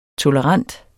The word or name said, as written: tolerant